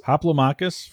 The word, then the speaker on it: Hoplomachus: Hoplomachus from Chip Theory Games.